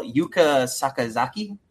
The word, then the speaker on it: Yuka Sakazaki: And forgive me for pronunciation. Impact Wrestling will take the magical girl, Yuka Sakazaki. – I think that was, you got it right.